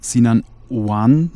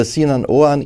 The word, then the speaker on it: Sinan Oğan: Jetzt könnte es auf einen Mann ankommen, den die wenigsten von uns wahrscheinlich schon mal gehört haben, Sinan Oan, ich hoffe, ich habe es richtig ausgesprochen, äh, der dritte richtig.